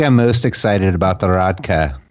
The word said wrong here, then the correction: rakia: at Belgrade. – Rakia, yes. – Rakia, yes. I always say it wrong, and for those of you that don't know what that is, it's basically, as Dan described it to me, Serbian moonshine.